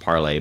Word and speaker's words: Parler: And of course, he's talking about Parler, which is a, or pronounced Parlay by many.